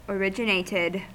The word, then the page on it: originated: American English